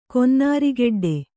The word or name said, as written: ಕೊನ್ನಾರಿಗೆಡ್ಡೆ